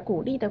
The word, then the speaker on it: 鼓励: 这个孤立的孤是一声，而鼓励的鼓是三声。你把孤立和鼓励这两个词的声调搞混了。